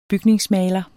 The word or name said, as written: bygningsmaler